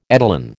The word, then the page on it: etalon: American English